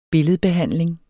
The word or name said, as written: billedbehandling